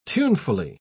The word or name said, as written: tunefully